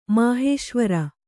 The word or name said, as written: ಮಹೇಶ್ವರಿ